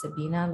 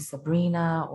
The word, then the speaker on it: Sabina: So Sabibi Jewels, well, first of all, Sabibi was kind of like my nickname in high school because for some reason it was hard for people to pronounce Sabina. Like they would usually call me Sabrina or like Sabio or whatever.